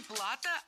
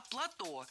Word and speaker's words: плато: А после, через некоторое время, через несколько тысяч лет, океан отступил, и осталось, получается, такое песчаное плато, которое было скрессовано. – Не плато, а плато. Слово это тоже французское и тоже сохраняет ударение на последнем слоге.